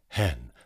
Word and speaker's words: heaven: And note, of course, that heaven is pronounced with one syllable here, hen, though hen knows it is but as a tomb.